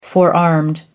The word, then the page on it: forearmed: American English